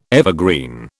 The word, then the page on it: evergreen: British English